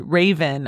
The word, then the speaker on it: Ravyn: And originally I said Ravin, but I think that her name is probably pronounced Raven, R-A-V-Y-N. Last name is Lene, L-A-L-E-N-A-E.